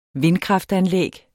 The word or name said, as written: vindkraftanlæg